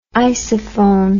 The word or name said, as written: isophone